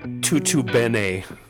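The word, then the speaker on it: tutto bene: Tutu bene. Say it with me. Tutu bene. – Tutu bene. – Tutu bene. – It's spelled bene. It's spelled B-E-N-E.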